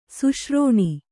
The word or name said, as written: ಸುಶ್ರೋಣಿ